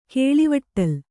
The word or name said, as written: ಕೇಳಿವಟ್ಟಲ್